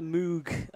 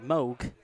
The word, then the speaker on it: Moog: And I've got the Moog, or Moog, depends how you say it, the multi-mode filter collection, which is currently going for £189.